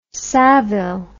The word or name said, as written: savile